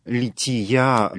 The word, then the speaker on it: лития: Уже было сказано о том, что лития, собственно, правильное ударение в церковнославянском, это часть вечерней, входящей в состав всенощного бдения, когда духовенство переходит из алтаря в самую западную часть храма.